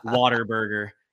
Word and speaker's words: Whataburger: Every true Texan knows it's Whataburger, okay? Or Whataburger. Sorry. He's getting on me for the pronunciation. That's fine. Whataburger.